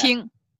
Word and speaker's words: Qing: Qing.